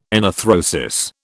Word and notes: enarthrosis: British English

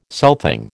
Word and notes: southing: American English

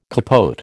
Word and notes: capote: American English

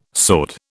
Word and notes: sword: British English

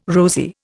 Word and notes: rosy: Indian

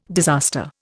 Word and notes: disaster: Indian

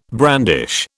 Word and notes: brandish: British English

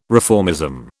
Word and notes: reformism: British English

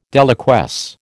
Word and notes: deliquesce: American English

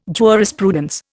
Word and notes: jurisprudence: Indian

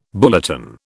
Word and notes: bulletin: British English

